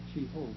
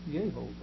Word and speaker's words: Yehovah: And so it's been pronounced Jehovah. It would have been better had he pronounced it Yehovah. Actually, the first syllable is Yah, as we've already indicated, the name Yahweh.